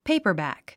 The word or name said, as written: paperback